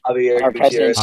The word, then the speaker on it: Javier Gutierrez: I always forgive me if I pronounce his name wrong, but Xavier Gutierrez. – Javier Gutierrez.